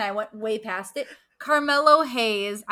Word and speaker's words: Carmelo Hayes: We have Camilo Hayes going against Giovanni Vinci for the North American. – Love, what? there is an R in that. You said Camilo.